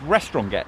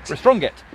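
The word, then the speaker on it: Restronguet: And we are walking along what is pronounced, I believe, Restronget. It looks like it should be pronounced Restronget. – Yes. – It's very French, but it's... – Restronget.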